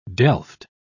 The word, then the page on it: delft: British English